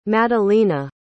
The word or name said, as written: Madelina